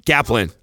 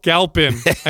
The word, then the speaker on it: Galpin: We interview Dr. Andy, how do you say his last name? – Galpin. – No, Galpin. I knew you'd say it wrong.